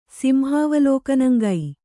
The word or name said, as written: ಸಿಂಹಾವಲೋಕನಂಗೈ